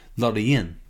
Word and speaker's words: Loreen: But we're, of course, looking forwards into the future to Loreen, or as you'd say in Sweden, Loreen.